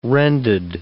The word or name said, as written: rended